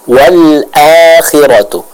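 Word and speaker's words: wal akhiratu (والآخرة): Selanjutnya untuk Umu Abdullah masih pada huruf hamzah di ayat yang ke-17 wal akhiratu ya, sama dibaca dengan jelas, bukan wal a, nah, jangan nanggung suaranya. Jadi yang Umu Abdullah baca itu masih nanggung, dibilang hamzah enggak, dibilang ain juga enggak ya, jadi harus disempurnakan pengucapan hamzahnya dari tenggorokan bagian bawah. Wal akhiratu, wal akhiratu, seperti itu.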